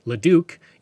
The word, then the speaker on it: Leduc: He asked Father Lacombe to name stations along the Edmonton-Calgary railway line, and he would suggest several names, including Leduc, which is now pronounced Leduc, in honor of a fellow missionary, and Millet, or Millet, for the travel companion who joined him on so many journeys.